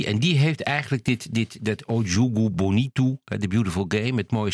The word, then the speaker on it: O Jogo Bonito: Je schrijft op O Jogo Bonito, maar zo spreek je het dus totaal niet uit. – O Jogo Bonito.